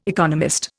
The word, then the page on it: economist: Indian